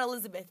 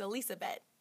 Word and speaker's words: Elizabeth: My middle name is a tribute to her, and it's spelled just like Elizabeth, which is kind of frustrating because then I tell people, oh, it's not Elizabeth, it's Elizabeth. And then people have trouble pronouncing it.